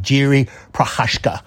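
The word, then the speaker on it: Jiří Procházka: I actually got the exact pronunciation from YouTube. Jiri Prochazka. So Jerry Prochazka is the four to seven favourite here.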